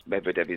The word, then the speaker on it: Medvedev: How do you say his name? – Medvedev. – Medvedev, I do apologise, yeah.